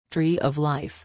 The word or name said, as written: tree of life